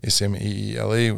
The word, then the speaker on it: Smeele: It's just my first and last name, Brad Smeele, Smeele, which Smeele. – Is spelled. Well, it's weird Dutch pronunciation, but...